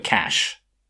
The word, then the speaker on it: cache: More importantly then, can you tell us how you're supposed to pronounce cash or cache, C-A-C-H-E, like you have in memory in computers? – That's a good one. I'm willing, grudgingly, to take your direction on this. But I would, of course, call it cash.